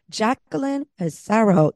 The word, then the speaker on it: Jaclyn Passaro: Today, my guest is Jaclyn Passaro.